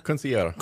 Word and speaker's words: quinceañera: Also, your private parties, your bar mitzvahs, quinceañeras. Is that how you say that? Quinceañera? – Quinceañera.